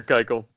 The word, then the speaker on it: Keuchel: Only if you pronounce his name right. – Yeah, that's true. Keuchel. My bad.